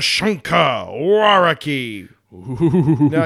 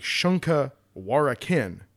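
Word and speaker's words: Shunka Warak'in: To visit the Shunka Waraki. Now, the way it's spelled, it looks like Shunka Warakin. – Mm-hmm. – But it's actually pronounced Shunka Waraki.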